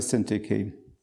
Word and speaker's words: Syntyche: Syntyche has got a few variations, I believe, but we'll stick with Syntyche.